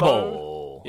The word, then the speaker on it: CONMEBOL: So is it CONMEBOL? – CONMEBOL. Yeah. All right.